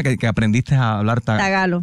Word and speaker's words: tagalo: Hay un lenguaje que se llama trágalo. – Tagalo. – No, tagalo.